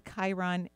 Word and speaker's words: Chiron: We're in these final steps with Chiron is in Pisces. That's the correct way to say it.